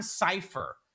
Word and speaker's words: Sipher: And just for the record, I want to hear your opinion on this, but just for the record, this guy's name is John Cipher, as in Lucifer, but it's spelled S-I-P-H-E-R.